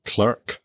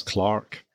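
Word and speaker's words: Clerke: And there's another illness on board, which is poor old Captain, is it Clerk? – Yes. – Clark. – I think they pronounce it Clark, or back then they pronounced it Clark.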